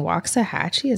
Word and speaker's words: Waxahachie: Yeah, the song is Dollar Store by Ben Queller, featuring Waxahachie. Is that how it's pronounced? – Yes.